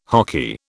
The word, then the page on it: hoky: British English